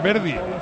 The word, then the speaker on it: Verdi: ¿Está Bardi máximo goleador? – Diría que no, pero te lo voy a mirar. – Vale. Paco, ¿por qué pronuncia mal? No es Bardi, es Verdi.